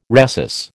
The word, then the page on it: rhesus: American English